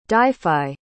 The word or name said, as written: Dyfi